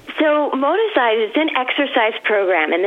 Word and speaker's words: Moticise: Now, this idea, how do you say it, Moticise? – Yeah, it's actually Moti from motivation and size from exercise. So it's fusing the two words. It's motivational exercise. – Moticise.